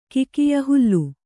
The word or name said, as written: ಕಿಕಿಯ ಹುಲ್ಲು